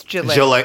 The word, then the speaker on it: Ghislaine: You see, they got Ghislaine Maxwell. She's going away for 20 years. Like, what makes that situation, and I know upsets you too, with Ghislaine. – Ghislaine. It's Ghislaine.